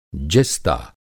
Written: जिस्ता

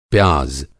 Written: प्याज़

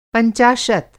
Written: पंचाशत

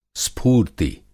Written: स्फूर्ति

